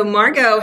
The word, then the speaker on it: Maergo: So Maergo has a secret E in it. So it rhymes with cargo, but it's Maergo.